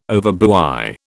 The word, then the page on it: overbuy: British English